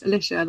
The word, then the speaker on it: Alicia: It's spelled not phonetically, so it's spelled like Alicia. So I always had to be like, oh, no, it's Alicia, like Patricia. People call me Lish, and then one of the girls misheard and said Squish.